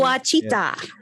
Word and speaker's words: Ouachita: Oh, Ouachita.